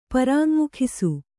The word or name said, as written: ಪರಾಙ್ಮುಖಿಸು